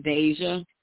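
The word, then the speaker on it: Daja: My name is Deja.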